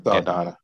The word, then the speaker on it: Saldana: Saldana.